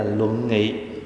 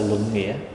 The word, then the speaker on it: luận nghị: Tại vì trong cái bản tiếng Hán để là luận nghị chứ không phải là luận nghĩa. Nhưng mà nếu nói chữ nghĩa cũng được, tức là chúng ta bàn về cái nghĩa của nó, nhưng mà mình nên đọc đúng cái tên phẩm, gọi là luận nghị.